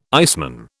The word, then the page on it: iceman: British English